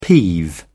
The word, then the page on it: peeve: British English